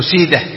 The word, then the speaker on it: سيده: قال وقال ابن منده، وكان ابو داود ابن منده، منده دائما بالهاء، منده وقفا ووصلا، اسم فارسي، منده، منده وسيده، ابن سيده ايضا بالهاء وقفا ووصلا، وماجه، هذه الثلاثه كلها بالهاء، ماجه ومنده وسيده، ما يقال ماجه منده لا خطأ، لأنها أسماء فارسية تنطق بالهاء وقفا ووصلا.